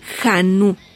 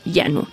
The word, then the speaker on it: Jhanu: Se escribe J-H-A-N-U Janu, pero se pronuncia Yanú, marcando un hito como la primera en su tipo en combinar estas influencias de manera exitosa.